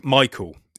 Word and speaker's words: Mikkel: The skater they're kind of following is this Nick Michael. It's spelt Mikkel, but he pronounced it Michael, so I'm gonna go along with it. Nick Michael, and he is a ripper.